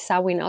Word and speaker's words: Samhain: Das heißt Samhain oder Samhain geschrieben. Es wird aber eigentlich Samhain ausgesprochen.